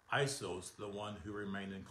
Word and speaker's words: Iesous: I-E-S-O-U-S, and it's pronounced Iesous, and that was the name that was given to Jesus. And it's pronounced J-E-S-O-U-S, which would be Jesus in the Greek.